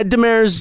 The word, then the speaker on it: Demers: I'm saying your name right, Deemer's, right? – Demers. – Demers. – Yeah, it's a. – I just realized. – Yeah, no, no problem. – Demers is a more French kind of.